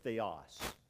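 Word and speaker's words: theos (θεός): And when you see that, you pronounce it theos.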